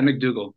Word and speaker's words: MacDougall: Now there's other characters throughout the book that are all related, but the three main characters are, I believe it's MacDougall. Am I saying that right? MacDougall? – Yeah, McDougal. Yeah.